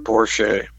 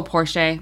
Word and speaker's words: Porcher: The Lions' final game there on January 6, 2002, lacked nostalgia, with players like Robert Porcher criticizing the stadium's artificial turf. – Oh, Robert Porsche. – Oh, Porsche. My bad. – Yeah, that's how it's pronounced.